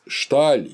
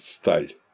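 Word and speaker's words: Staal: Ну и, наконец, Фриц Шталь, или когда его по-голландски произносят Сталь, называл наукой ритуала.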